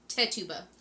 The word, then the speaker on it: Tituba: Tituba. – Thank you. – Yeah. Oh, no, I'm sorry. I looked it up last night. It's Tituba.